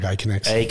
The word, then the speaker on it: Aconex: He is a co-founder and former CEO of Aconex, if I said that correctly. – Yeah, we'd say with a bit of an Australian accent, like Aconex.